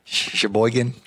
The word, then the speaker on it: Sheboygan: How do you say it? Sheboygan. Sheboygan. Sheboygan.